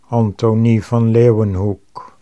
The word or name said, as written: Anthonie van Leeuwenhoek